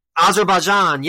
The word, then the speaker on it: Azerbaijan: Azerbaijan, yes, okay. Azerbaijan, yes.